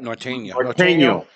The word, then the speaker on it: Norteño: Now, one of the interesting cigar brands, Norteño, am I saying that right? – Norteño.